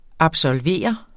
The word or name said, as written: absolver